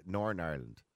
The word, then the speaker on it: Northern Ireland: You don't pronounce the T. Northern Ireland.